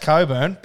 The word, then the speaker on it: Cockburn: And they said, nah, it's pronounced Coburn, but that's how it's spelled.